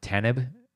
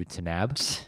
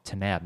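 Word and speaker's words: Taanab: Tanib and it's pronounced in the movie Tanab.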